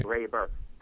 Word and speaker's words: Garber: Yeah, it's Graber, and it's spelled G-A-R-B-E-R. – Right. – Graber.